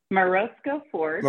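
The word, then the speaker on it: Morozko Forge: I'm pardon me if I'm mispronouncing it. Morosco Forge, is that right? – Morosco Forge. – Morosco Forge, yeah.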